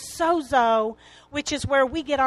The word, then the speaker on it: sozo: The root word for salvation is a Greek word pronounced sozo, S-O-Z-O.